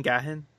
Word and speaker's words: Gaghan: G-A-G-H-A-N. I think I'm saying that right. Gaghan.